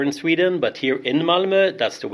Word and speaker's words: Malmö: That's the proper pronunciation, not Malmö. Malmö. We're here Malmö, in the... Yeah. How do you say it? – Malmö. – Malmö. – Correct. – So it's not Malmö, it's Malmö. – Yeah, it's the Swedish letter Ö.